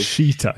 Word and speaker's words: Rashica: It's we all fail to pronounce Rashica's name properly, including myself, because Rashica, is it? – Rashica.